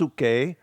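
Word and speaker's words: psuche (ψυχή): The second word there, so it says, I pray God your whole spirit, that's pneuma, your soul, that is the Greek word psyche, actually in the Greek it's pronounced psuche, but the psyche, that is the mind, there is a thinking part.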